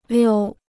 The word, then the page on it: 溜: liū